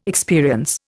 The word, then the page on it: experience: Indian